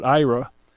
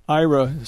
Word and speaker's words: Aira: It's called Aira. And Aira is spelled A-I-R-A.